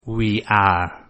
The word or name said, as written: we are